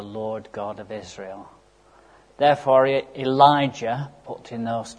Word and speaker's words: Elijah: John, as you remember, confronted King Herod, And here in 1 Kings 17, we see Elijah confronting King Ahab. The name Elijah, by the way, can be split into two parts. Eli-yah, as it's pronounced in Hebrew.